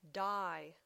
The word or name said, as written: dye